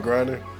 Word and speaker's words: Griner: This is Brittany Garner. Did I say the name right? – Brittany Griner. – Griner. Griner. I said Garner. Griner.